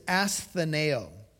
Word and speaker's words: astheneo: The Greek word is astheneo. It is spelled A-S-T-H-E-N-E-O, astheneo.